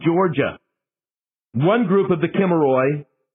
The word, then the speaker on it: Kimmeroi: That's how the Greeks pronounced it, the Kimmeroi. K-I-M-M-E-R-O-I.